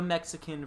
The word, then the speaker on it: Mexica: So I come across this term, and it's like, what is like the Mexican versus like. Like the demonym or the federal political identification of being Mexican. And Keke told me that there's a different pronunciation. It's the Mexica.